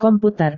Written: komputer

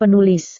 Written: penulis

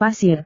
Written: pasir

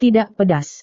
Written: tidak pedas

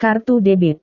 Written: kartu debit

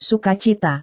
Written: sukacita

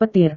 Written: petir

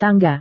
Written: tangga

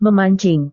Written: memancing

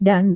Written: dan

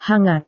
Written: hangat

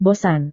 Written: bosan

